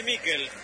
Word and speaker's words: Mikel: ¿Cómo le decimos? ¿Michael o Mikel? Nicolás Álvarez, usted que es especialista en nombre. Se escribe Mikel. ¿Cómo le digo? – Decile Miquel. Entonces le digo a Miquel, usted me enseña y yo aprendo.